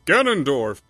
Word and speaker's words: Ganondorf: Ganondorf.